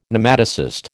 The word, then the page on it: Nematocyst: American English